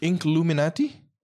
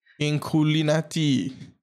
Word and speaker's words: Inkulinati: But how do you spell it? Incluminati? – Yeah, I-N-K-U-L-I-N-A-T-I. – Inculinati.